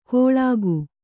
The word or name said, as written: ಕೊಳಕು